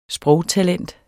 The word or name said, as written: sprogtalent